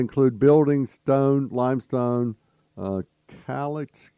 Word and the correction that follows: caliche: how you pronounce that? Caliche.